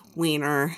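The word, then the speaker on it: Wiener: I believe it's pronounced Weiner.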